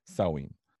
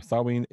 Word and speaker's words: Samhain: So the story of Halloween dates back thousands of years ago to ancient Celtic traditions and one specifically called Samhain. Now, Samhain is a Gaelic word and it's spelled S-A-M-H-A-I-N and is often mispronounced by many people as Samhain.